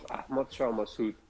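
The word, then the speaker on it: Ahmad Shah Massoud: And isn't it Ahmed Sarah Massad? Is that the war hero? Did I say that right? – Yeah. That was Ahmed Shah Massoud.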